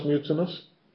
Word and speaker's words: mutinous: ¿Cómo pronunciamos mutinous? Mutinous. Mutinous. Mutinous. Mutinous. O sea, normalmente, si se pierde una sílaba, es la penúltima sílaba débil.